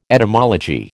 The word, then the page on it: etymology: American English